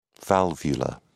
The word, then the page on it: valvula: British English